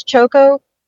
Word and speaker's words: Choco: I've always pronounced it with two long O's, Choco, but it's an alias.